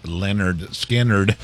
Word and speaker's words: Lynyrd Skynyrd: 53 years ago, 1973, Lynyrd Skynyrd released their debut album, pronounced Lynyrd Skynyrd.